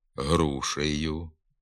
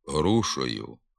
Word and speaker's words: грушею: Наприклад, «динею», «працею», «грушею», «гаєві», «товаришеві», «гаєм», «товаришем». Вимова тут замість «е» звука «о» – «динею», «працею», «грушою», «гайові», «товаришові», «гайом», «товаришом» відома в багатьох говорах, не відповідає літературній нормі.